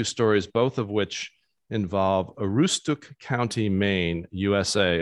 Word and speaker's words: Aroostook: I say Aroostook.